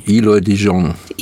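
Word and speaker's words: Eloy de Jong: Wie spricht man den? – Eloy Dijon. – Eloy, ja genau.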